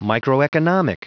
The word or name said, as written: microeconomic